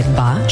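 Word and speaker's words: ba (八): U modernoj Kini reč za broj 8 izgovara se ba, što je slično reči fa, koje u slobodnom prevodu znači imućan ili brzo stečeno bogatstvo.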